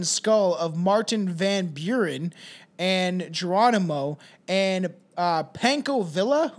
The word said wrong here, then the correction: Pancho Villa: Who's Panko Villa? – Pancho Villa. – Oh, Pancho. Oh, that makes sense.